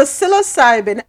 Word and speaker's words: psilocybin: So the psilocybin, am I saying it right now? Psilocybin. – Yes.